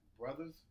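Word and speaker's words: Bruthas: Brothers on Tennis.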